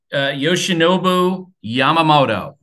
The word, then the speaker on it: Yoshinobu Yamamoto: Yoshinobu Yamamoto, who's going to yep.